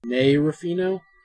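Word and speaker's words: Nei Ruffino: Inks are Eau Claire Albert, and colors by, how do you say this name? Nell Ruffino? – Oh, I think that's an I. Nay Ruffino? – Nay Ruffino? Okay.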